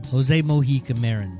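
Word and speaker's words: José Mojica Marins: Jose Mocha? How do you pronounce his name? – Jose Mojica Marin.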